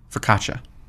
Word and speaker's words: focaccia: Focaccia.